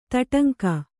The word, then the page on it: ತೊಡಂಗ: Kannada